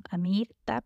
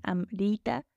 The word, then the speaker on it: Amrita: Yo lo leo siempre como Amirta, pero es Amrita.